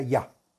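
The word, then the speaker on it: Yah: Yud Hei, que se pronuncia ya.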